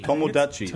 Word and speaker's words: Tomodachi: Is it, do you pronounce the O after the T or is it Tamodachi? – Tomodachi.